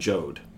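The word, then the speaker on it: Joad: But I don't think it's by accident the family is named Jode. It rhymes with Job.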